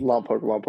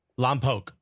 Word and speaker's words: Lompoc: I believe you're from Lompoc. I don't know if I'm pronounced that correctly. – Lompoc. – Lompoc. Okay. – Yeah. – If for everyone listening, it's L-O-M-P-O-C. You pronounce that without me.